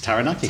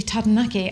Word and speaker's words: Taranaki: And then someone said, you know, we heard a Maori person pronounce it properly, and they said, oh, it's actually Taranaki, and it sounds so beautiful when it's pronounced yeah, the sound, way it yeah, should it be.